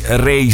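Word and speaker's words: Raye: Ecco, per il disco extra rock, il disco non rock della settimana, chiudiamo con Ray. Lei si chiama Rachel Agatha Keane, nata in Inghilterra, a Londra, alla fine degli anni 90, credo che abbia poco meno di 30 anni. Ray si scrive R-A-Y-E, è una cantautrice appunto britannica di pop contemporaneo, R&B, con puntatine nel soul, nel jazz, nel songwriting classico.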